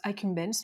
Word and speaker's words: accumbens: Alors vous le prononcez comme vous voulez, moi je le prononce accumbens.